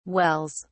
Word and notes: wells: British English

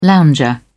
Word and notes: lounger: British English

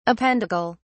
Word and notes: appendical: British English

